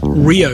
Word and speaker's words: REO: REO Speedwagon got their name from a manufacturer of trucks, pronounced Rio Speedwagons.